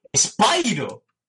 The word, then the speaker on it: Spyro: Pues me sorprendió escuchar que llamaban a Spyro ¡Spyro! Y es como, hostia, pues claro, tiene razón, tiene lógica que ese SPI se pronuncie Spyro.